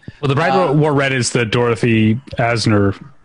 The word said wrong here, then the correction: Arzner: Arzner. How do you say her last name? – Arzner. – Yeah.